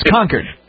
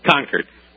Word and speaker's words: Concord: It's Concord. – Concord.